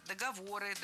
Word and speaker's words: договоры: Ну и как бы все это попытать весь ремонт свалить, естественно, свалить на вас, потому что там хорошие юристы, и договора составлены. Правила. – Договора. Неверно. Все та же ошибка. Передвинули ударение, а этого делать не стоило. Правильно. Договоры, договоров, договорами, договорах и так далее.